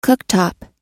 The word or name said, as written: cooktop